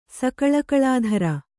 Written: ಸಕಲಕಲಾಧರೆ

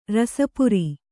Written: ರಸಪುರಿ

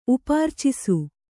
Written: ಉಪಾರ್ಚಿಸು

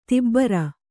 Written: ದುಬಾರಿ

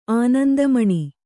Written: ಅನಂತಮಣಿ

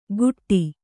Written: ಗುಟ್ಟಿ

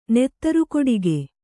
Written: ನೆತ್ತರುಕೊಡೆಗೆ

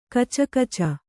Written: ಕುಚುಕುಚು

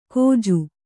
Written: ಕೋಜು

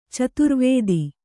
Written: ಚದುರುವಾತು

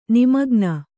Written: ನಿಮಗ್ನ

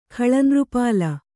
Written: ಖಳನೃಪಾಲ

